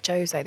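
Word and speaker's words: José: No, it's Portuguese. – What do you mean? – Jose. They pronounce the J's.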